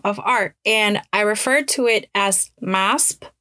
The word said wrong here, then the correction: MASP: And after the episode aired, a listener commented that the correct pronunciation would be MASPI.